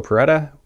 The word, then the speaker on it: Porretta: I've got Francesco Porretta with me today, and I could pronounce it super Italian, but you don't pronounce it super Italian, so I guess that'll do.